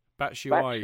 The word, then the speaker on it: Batshuayi: Is that the official pronunciation? – Yes, Batshuayi? I believe so. Batshuayi. – Batshuayi. – Batshuayi. So I'm told.